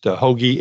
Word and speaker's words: Hoge: It's pronounced Hoagie, H-O-G-E, and Tom has been moving up the ranks in the PGA.